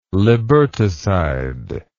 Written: liberticide

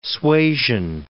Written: suasion

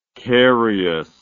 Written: cariousness

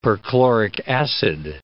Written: perchloric acid

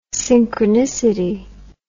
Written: synchronicity

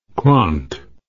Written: quant

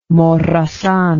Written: Morazan

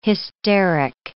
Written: hysteric

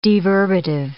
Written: deverbative

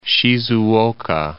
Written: Shizuoka

